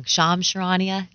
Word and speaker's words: Shams Charania: I always say his name wrong. Shams Charania? – Yeah, you got it right.